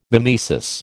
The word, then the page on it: mimesis: American English